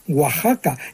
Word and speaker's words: Oaxaca: Entonces, esa palabra así escrita se pronunciaba Oaxaca, y hoy también, porque se ha mantenido, fíjate, la grafía española del siglo XVI.